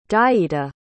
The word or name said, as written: dayita